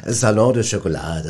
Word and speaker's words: Salon du Chocolat: Ja, das Salon de Chocolat, das hast du schon ganz schön ausgesprochen, schon ganz richtig.